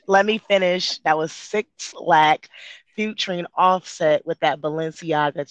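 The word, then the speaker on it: 6LACK: I literally saw, I follow him on Instagram, and I swear I saw him post something that was like, it's pronounced Black. Like, it literally said it's pronounced Black. – I saw it on Google that it was pronounced Black. – Oh my goodness, that man named Six Lack.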